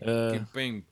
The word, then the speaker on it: Kimpembe: Renato Sanches is not available for this game, and neither is what's his name, Kimpembe. Did I say that right, Chris? I know that you're a follower of Ligue 1. – Yeah, oui, je porte très bien. – Oui, Kimpembe.